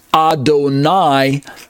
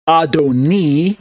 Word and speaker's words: Adonai: Adonai is marked out by the Masoretes as having the kamatz under the letter N, which gives it the pronunciation Adonai, not Adoni.